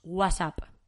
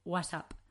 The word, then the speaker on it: WhatsApp: En español decimos WhatsApp, ¿vale? WhatsApp. ¿Por qué? Pues porque es nuestra pronunciación, es como mejor nos viene decirlo y es así como nosotros lo hacemos.